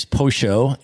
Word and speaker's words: pollo: I was just in Argentina 2 months ago, and there they pronounce the double L sound like a sh. So the word for chicken is pollo in Argentina, right?